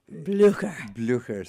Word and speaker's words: Blücher: And so, you're the the Frau Blüchner. – Blücher. – Sorry. And one does not dare say it wrong to a German, right?